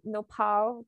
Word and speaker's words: nopal: Just for an example, you had that nopal cactus. – Mm-hmm. – Am I pronouncing that right? – Yes, yes, yes okay. you are.